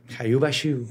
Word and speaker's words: Hoia-Baciu: Hoyubashu. Hoyubashu. – ¿Hoyubashu? – Que se escribe Hoya, H-O-I-A-B-A-C-I-U. Pero el rumano es Bashu. – Hoyubashu. – Hayubashu. – Hayubashu.